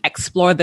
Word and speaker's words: Actsplore: And from there, the name Actsplore this, right? I think you would know it's a word play on explore, but it's spelled A C T S P L O R E. And we wanted to have an action oriented twist to the word of explore.